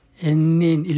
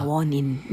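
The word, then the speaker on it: 엔닌: 엔린 일본말로 일본 그 발음으로 엔린인데 네. – 우리 한자로 읽으면 원인입니다. – 아, 원인.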